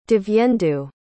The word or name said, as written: divyendu